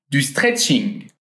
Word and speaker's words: stretching: Notez bien ici que le mot est en anglais, mais on le prononce d'une façon très française. Du stretching.